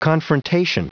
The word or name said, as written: confrontation